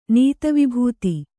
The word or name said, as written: ನೀತವಿಭೂತಿ